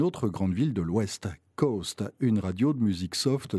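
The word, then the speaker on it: KOST: Le reste du temps, Coast, K-O-S-T, K-O-S-T pour le call letters, mais prononcé phonétiquement coast, comme la côte, sous-entendu ouest.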